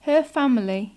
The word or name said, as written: her family